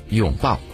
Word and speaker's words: 拥抱: 因为这个字呢在我们内地读作拥，叫做拥抱，可在台湾呢读作拥抱。